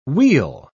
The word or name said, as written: we'll